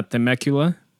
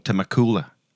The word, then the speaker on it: Temecula: Cerca de Los Ángeles, mis primos me dijeron que fueron a Temecula y que está bien interesante. yo, lo había pronunciado como Temecula. Temecula.